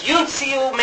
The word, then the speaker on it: uncial: The third group of manuscripts are called uncle. No. Unical, or U-N-I-C-I. Is it I-A-L? Yeah. U-N. I'm not. U-N-C-I-A-L. Uncial. That's how you pronounce it.